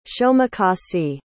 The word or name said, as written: shomecossee